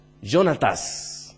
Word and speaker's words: Jonatás: ¿Cómo se dice en portugués? Jonatás. En español es Jonatán. En inglés es Jonathan.